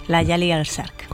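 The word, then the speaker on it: Layali al-Sharq: Entonces hemos convenido que esto pertenece a la Al-Ahram Orquesta y se titula Layali al-Sark. ¿Lo he dicho bien? – Sí, Layali al-Sark.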